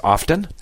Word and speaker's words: often: I say often. – Often. Yeah. – And that I say often sometimes. It just kind of depends.